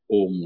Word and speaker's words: Om: In Tamil, there's a gentleman who has put it, Skandithya has put OM, because you can also pronounce as AM also in Tamil, but actually the our forefathers have pronounced it as OM only.